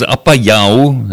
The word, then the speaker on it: Apayao: Apayao, dis eintlik skies, die korrekte uitspraak is Apayao.